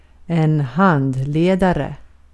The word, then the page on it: guide: /ɡaɪd/